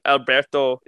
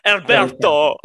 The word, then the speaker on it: Alberto: Like, Alberto is... Wow, I just said that. That's not even how you say it. Alberto!